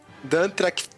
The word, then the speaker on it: Dan Trachtenberg: Dan Trachtenberg.